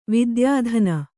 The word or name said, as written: ವಿದ್ಯಾದಾನ